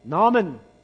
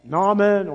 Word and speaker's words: Naaman: We see there was a man called Naaman. Naaman or Naaman. However you want to pronounce it. It's got two A's there and one A in the main part.